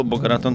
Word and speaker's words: Boca Raton: Oh, Boca Raton too.